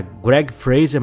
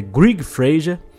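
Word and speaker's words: Greig Fraser: Fotógrafo que brilha demais, eu tive que pesquisar a pronúncia do nome dele, porque eu achava que era Greg Fraser, mas na verdade é Greg Fraser.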